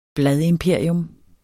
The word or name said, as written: bladimperium